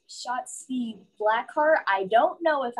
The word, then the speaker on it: Shotzi: She's it's like yelling Yahtzee, but Shotzi.